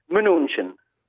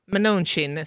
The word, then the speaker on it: Mnuchin: Alla news la pronunciano il nome Mnuchin. – Mnuchin, sì, esatto, come un po' diceva anche Vittorio Gaudio.